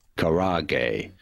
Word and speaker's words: karaage: K-A-R-A-A-G-E. Okay, this is how you pronounce it. Next time you're allowed to go to a Japanese restaurant. – Karage. – There's no way I'm saying that. Karage. – Karage. – That's easy to read. Karage.